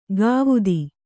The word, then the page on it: ಗಾವುದಿ: kannada